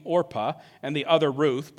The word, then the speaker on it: Orpah: By the way, yes, Oprah Winfrey is named after Orpah. Her family just didn't know the story well, and so they mispronounced it, and she's Oprah, but she's really named after this person here.